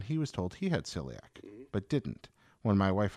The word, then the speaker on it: celiac: Timothy Wisenhunt says, and I hope I'm pronouncing this correctly, I have celiac, is that correct? – Yeah, celiac, yeah.